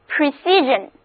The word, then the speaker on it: precision: 同样的字母组合 sion 读作人，跟我读就是 precision，意思是精确精密精密度。